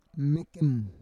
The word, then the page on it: mekem: Roon